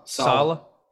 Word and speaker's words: Saleh: I might not pronounce this right. So the Jets head coach is Robert Saleh. – Sala.